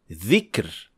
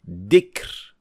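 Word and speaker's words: dhikr (ذكر): So, for example, saying dhikr as dikr with de sound. These two sounds are completely different, and some learners make the mistake of pronouncing it as zay. So instead of saying dhikr, they would say zikr.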